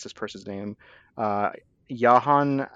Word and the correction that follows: Yahya: Abdul-Mateen. I'm not sure if I'm pronouncing his name correctly. I apologize. – Yahya Abdul-Mateen. Yeah. Yahya. – Yahya. Okay.